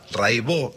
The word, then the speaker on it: Raibaut: Buenísimo, Cata Raibó. Se escribe Raibaus, métanse, búsquenla, Rai con Y, Baut con B larga. Catalina Raibaut, Raibó se dice, y bueno, Cotidiano son 10 canciones, hay un documentalito muy lindo.